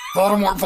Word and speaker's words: Voldemort: Technically, you don't pronounce the last T. – Yeah. – But also, you shouldn't say his name. – Yeah. Voldemort.